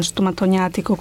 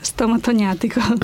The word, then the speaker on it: stomatognatico: L'apparato stomatognatico. Detto bene? – Sì, ha detto benissimo.